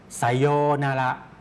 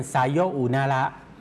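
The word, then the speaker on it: ไ ซโ ย นา ระ (さようなら): คนญี่ปุ่นจะมักจะออกเสียงควบออกเสียงรวบครับออกเสียงยาวนะครับไซโยนาระครับถ้าออกเสียงช้าๆช้าๆจะเป็นไซโยอุนาระ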